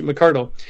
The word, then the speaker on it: McCardle: I was worried about saying his name wrong, but it's Scottish, McCardle.